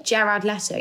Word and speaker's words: Jared Leto: I don't know, Jared Leto. Am I saying that right? – Jared Leto, yeah.